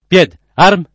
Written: pied’ arm

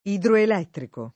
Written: idroelettrico

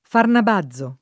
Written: Farnabazo

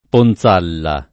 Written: Ponzalla

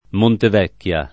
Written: Montevecchia

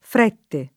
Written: Frette

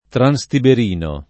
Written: transtiberino

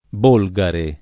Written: Bolgare

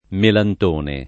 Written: Melantone

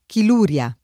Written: chiluria

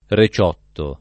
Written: reciotto

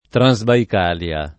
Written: Transbaicalia

